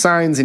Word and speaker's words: Saenz: His last name is spelled Saenz, and it's pronounced signs,